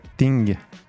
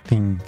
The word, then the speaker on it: thing: Mungo Zaifai avec la chanteuse que vous avez entendue, Aziza Jay, qui reprenait donc le fameux titre de Lauryn Hill, That Thing. Je prononce mieux comme ça. Thing, thing.